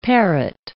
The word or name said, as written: parrot